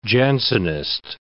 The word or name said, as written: Jansenist